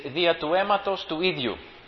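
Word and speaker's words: dia tou aimatos tou ideou (διὰ τοῦ αἵματος τοῦ ἰδίου): In the Greek, with his own blood, in the Greek, for those who are Greek speaking, is dia tou aimatos tou ideou. That's how you say it in the Greek.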